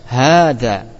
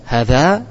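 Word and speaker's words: hadza (هٰذَا): Hada, meskipun dalam tulisan alifnya dibuang. Di situ ada alifnya enggak tulisan antum? Enggak ada. Namun dalam bacaan ada. Alif itu dalam bacaan itu ada. Kita baca hadza. Itu bacaan yang fasih. Kalau bacanya hadza berarti salah.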